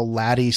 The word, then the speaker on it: LADEE: Well, yeah. LADEE is spelled L-A-D-D-I-E, right? Instead of L-A-D-E-E.